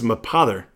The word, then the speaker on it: Mapother: So Tommy Cruise, born Thomas Cruise, how do you say that? – I think it's Mapother.